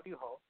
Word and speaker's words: Ryuho: Ryuho.